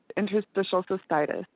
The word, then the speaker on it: interstitial cystitis: It's interstitial cystitis.